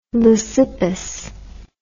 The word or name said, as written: leucippus